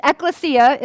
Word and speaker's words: ecclesia: It's ecclesia is the proper way to pronounce it. Ecclesia. I kept saying ecclesia. It just seems elite. But it's actually, I looked it up. I'm going, how do we pronounce this name? It's ecclesia.